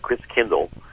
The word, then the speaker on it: Christkindl: And the German pronunciation of Christ Child is Christkindl. And over the centuries, like Kindercare, Kindergarten, Chris Kindle, over the centuries, it got pronounced Chris Kringle.